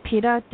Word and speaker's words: Pitta: Well, it's just very interesting, the descriptions, because I think that my combination would be the Pitta, did I say that correctly? – Yeah.